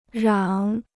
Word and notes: 嚷: rǎng

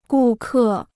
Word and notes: 顾客: gù kè

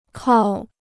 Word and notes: 靠: kào

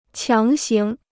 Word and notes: 强行: qiáng xíng; Taiwan pr. [qiǎng xíng]

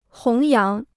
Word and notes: 弘扬: hóng yáng

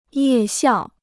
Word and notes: 夜校: yè xiào

